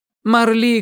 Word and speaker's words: Марли: «А по-настоящему?» «Марли Коупленд. Ударение на «и».